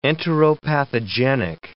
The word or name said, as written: enteropathogenic